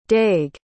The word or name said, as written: daeg